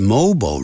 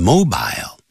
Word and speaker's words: mobile: They're designing a mobile, 或者是 mobile robot. 两个发音都可以，英式的发音念成 mobile, 在美式的发音都是念成 mobile, a mobile phone, a mobile phone, either is fine. 两个方法，两个念法都对。